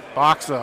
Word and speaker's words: Baxa: Baxa.